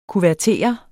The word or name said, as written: kuvertere